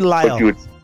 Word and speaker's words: Lisle: Lisle or Lisle? How would you pronounce it? L-I-S-L-E. – I think that is Lisle. – Yeah, Lisle, right? Because it's like aisle. Like if you got rid of the L, it'd be aisle, right? I-S-L, like an island, like a small island.